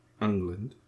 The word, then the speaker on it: Englund: freddy um, wasn't being played by robert englund or however pronouncing his name it's very close to england yep.